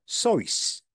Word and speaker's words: Seuss: Today, we pronounce Dr. Seuss like his surname rhymes with goose, but he pronounced it like it rhymed with voice, like Dr. Seuss.